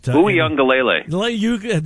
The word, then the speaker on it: Uiagalelei: Oh, Clemson's has played before in Ugalale, or butchering his name. But Uiagalelei.